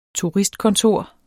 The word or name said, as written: TURISTKONTORER